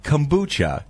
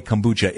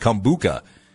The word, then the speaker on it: kombucha: I have no idea what they're talking about when they say kombucha. In fact, I thought it was pronounced kombuka.